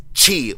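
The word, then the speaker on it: qi: From what I've learned, X-I is pronounced Chi, like S-H-I. Q-I is pronounced Chi, like C-H-I.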